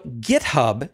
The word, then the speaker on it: GitHub: I'm joined this week by Jason Warner, the Chief Technology Officer at JithHub, although he pronounces it differently. Jason, welcome to the show. – Thanks, Corey. Good to be here. – So GitHub, as you insist on pronouncing it, is one of those companies that's been around for a long time.